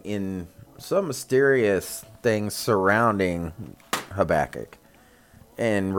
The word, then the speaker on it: Habakkuk: Yeah, and that's Habakkuk. And you're probably like, what? Yeah, that's how you pronounce